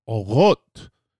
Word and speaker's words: Orot: Chanukah, Chanukah Sameach, Sufganiyot, Leviva, and let me say it right, Orot. And that ro is an R, a resh.